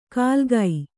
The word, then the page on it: ಕಾಲ್ಗೈ: kālgai